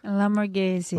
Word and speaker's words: LaMorgese: LaMorgese. – LaMorgese. Is that French? – Italian.